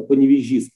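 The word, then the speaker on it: Паневежис: Ну, я, я только поправлю вот э, город, в котором давали концерт, он э, Поневежис правильно э, произносится, литовский город.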